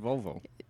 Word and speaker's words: Volvo: Yeah, as I said, Volvo. – The long O.